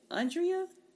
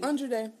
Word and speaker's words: Andra Day: Okay, so mine is Someday at Christmas by Stevie Wonder and is it Andrea? Andra Day? How you pronounce that lady? – Andra Day.